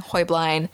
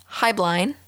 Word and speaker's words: Heublein: Now, the pronunciation of Heublein is debated, with the actual German pronunciation of the name being Heublein, then as they immigrated to the United States, becoming Heublein, and then once they were purchased by another company, Heublein.